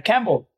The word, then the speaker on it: Campbell: His name is Campbell Lillard, and that's pronounced Campbell like the soup. Welcome, Campbell. – Thank you. What a great intro. – Every time I hear you introduce yourself to everybody, it's like Campbell like the soup.